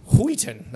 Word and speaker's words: Wheaton: Wheaton. That's how we pronounce it. Wheaton.